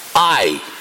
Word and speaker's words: Ai: Last week, our brother said he wasn't sure exactly how the word Ai is to be pronounced. Well, it's to be pronounced Ai.